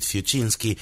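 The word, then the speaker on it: Fiuczynski: Vamos a escuchar ahora a David Fuchinski, un apellido con infinidad de consonantes, sí, pero bueno, se pronuncia así.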